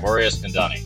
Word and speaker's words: Mori Eskandani: One of the most well-known poker producers and president of PokerGO, uh, Mori Did I pronounce that right, Mori Eskandani? – Yes, you did. Perfect.